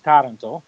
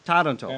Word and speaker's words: Taranto: Yeah, how do you go ahead and pronounce it? Because I'm butchering it. – Well, in Italian, it's Taranto. – Taranto. – And in English, you can say it whichever way.